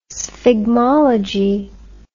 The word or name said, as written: sphygmology